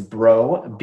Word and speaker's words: Breau: And then I saw you on YouTube and you said Breau. I said, okay, so that's kind of like B-R-O.